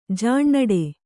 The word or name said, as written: ಜೇನಿಡು